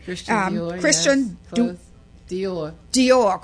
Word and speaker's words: Christian Dior: As a floater, now she'll tell us all about this, she worked with luxury brands such as Christine Dior. I said that right? – Christian Dior.